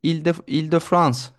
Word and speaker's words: Île-de-France: Paris çevrelerindeki Île-de-France, herhalde doğru telaffuz ediyorum.